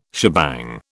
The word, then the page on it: shebang: British English